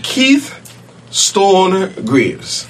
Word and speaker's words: Keith Stone Greaves: And that is the person of Mr. Keith Stone Greaves.